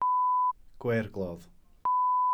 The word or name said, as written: gweirglodd